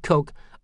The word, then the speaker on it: Koch: yes, that's pronounced Koch, are the financial engine of the Tea Party.